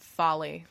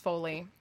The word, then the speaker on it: Foley: Foley is spelled F-O-L-E-Y, and it's pronounced Foley, not Folly.